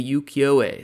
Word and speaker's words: ukiyo-e: Japanese woodblock prints, specifically the ukiyo-e style.